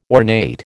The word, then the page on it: ornate: American English